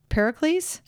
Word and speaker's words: Pericles: You know, at least like they sent, I'm going to say his name wrong again, Pericles? – Correct.